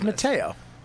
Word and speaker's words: Matteo: It's Matteo.